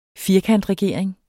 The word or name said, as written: firkantregering